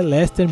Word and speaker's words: Leicester: E realmente a pronúncia certa é Leicester mesmo, tá galera? Então não se espante se ver escrito Leicester e o cara fala Leicester, o cara que falou tá certo.